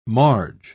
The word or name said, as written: marge